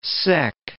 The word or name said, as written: sec